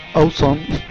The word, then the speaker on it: awesome: Otra cosa es que estemos hablando de Aguesome, escrito con W y pronunciado así con mi super inglés, awesome, más o menos.